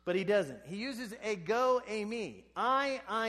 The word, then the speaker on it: ego eimi: But it starts with a phrase that is pronounced in English, ego eimi.